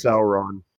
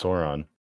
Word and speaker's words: Sauron: We've recorded for far too fucking long, and I don't have a whole lot of time this week to edit, so it's pronounced Sauron.